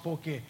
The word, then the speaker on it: poke: And it's pronounced poke.